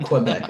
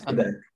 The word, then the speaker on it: Quebec: When I turned 18 and I was in Quebec, I pronounced it right, by the way. I'm pretty proud of myself. It's not Quebec, it's Quebec.